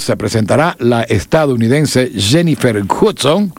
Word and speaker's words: Hudson: Bueno, se escribe Hudson, los gringos dicen Hudson.